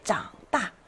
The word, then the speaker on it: 长: 이때 장다, 아, 다 자라서 컸다, 그 결과 컸다라는 뜻으로 장다라고 읽어야 맞습니다.